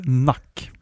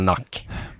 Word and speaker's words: NUC: ja det är inte de, de skriver dock på deras hemsida att man ska uttala det som NUC, eller NAC som mm. – lack eller jag tror de har, till och med har det som typ exempel så seriöst. Eh, say it like luck or truck.